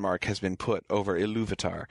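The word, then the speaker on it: Ilúvatar: One question that people will sometimes have is where does the stress fall in Iluvatar? And it tends to be the second syllable there, Iluvatar.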